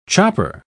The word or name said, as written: chopper